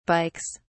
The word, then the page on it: bikes: British English